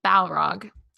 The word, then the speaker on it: Balrog: Because then we meet the Balrog. Is that how you say it? – Balrog.